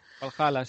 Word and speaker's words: Valhalla: Y es el que ha salido el tráiler de la historia de Assassin's Creed Valhalla. No sé si se pronuncia así. – Valhalla, sí. – Ah, eso, Valhalla.